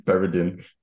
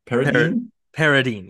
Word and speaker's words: Paradine: That it's Paradine. – Paradine. Yes. I wrote down a minute. Rhymes with Carradine.